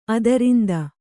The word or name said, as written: ಅದರಿಂದ